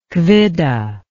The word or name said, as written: Quidde